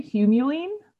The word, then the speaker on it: humulene: So it's spelled H-U-M-U-L-E-N-E. Yeah. And the pronunciation that they had was H-Y-O-O-M-Y-U-L-E-E-N. So I thought that was hyomyelene. – Yeah. That is definitely how I would read that. So I guess I'm wrong.